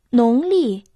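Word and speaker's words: Нонли (农历): Первый иероглиф «нон» произносится во втором тоне. В переводе на русский это «сельски», «сельскохозяйственно». Второй иероглиф «ли» произносится в четвертом тоне. В переводе «календарь». «Нонли» – китайский сельскохозяйственный календарь.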